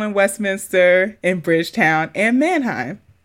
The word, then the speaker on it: Mannheim: Is that how you say it? – Right, looks like it. – Mannheim, okay.